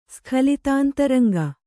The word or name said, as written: ಸ್ಖಲಿತಾಂತರಂಗ